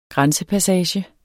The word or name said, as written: grænsepassage